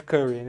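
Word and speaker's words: Currie: Então, é o Jeff Curry, né? Mas é meio afrancesado, até onde eu lembro, sobre o sobrenome dele.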